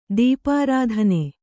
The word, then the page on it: ದೀಪಾರಾಧನೆ: Kannada